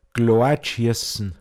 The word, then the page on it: Weidmoa: Pinzgauer Mundart